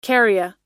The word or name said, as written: kerria